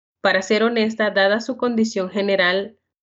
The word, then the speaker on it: honesta: How do you say honest? – Honesto, honesta.